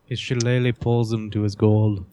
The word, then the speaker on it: shillelagh: His shillelagh. – His shillelagh. – His shillelagh.